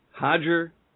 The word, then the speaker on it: Roger: Tim Kennedy, Hodger Gracie. – They're all spelled with an R, and they all sound like H.